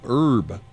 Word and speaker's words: herb: Do you say herb or herb? – I say the herb. – Herb, right. I think in the UK they say herb. Seriously. – Herb. – Like they pronounce the H in right. – the UK, but we don't.